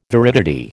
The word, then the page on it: viridity: American English